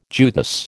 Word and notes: Judas: American English

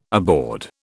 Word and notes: aboard: British English

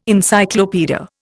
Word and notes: Encyclopaedia: Indian

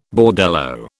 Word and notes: bordello: British English

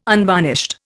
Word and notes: unvarnished: Indian

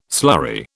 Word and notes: slurry: British English

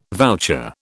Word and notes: voucher: British English

